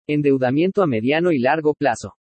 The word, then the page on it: ENDEUDAMIENTO A MEDIANO Y LARGO PLAZO: Spanish